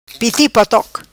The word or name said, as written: Piti potoc